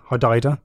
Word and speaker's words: Hodeidah: So yeah, the fighting has escalated massively over the last few weeks, and the Red Sea port of Hodeidah. Probably saying that wrong. Hodeidah. – Sounds good.